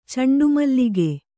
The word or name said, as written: ಚಂಡುಮಲ್ಲಿಗೆ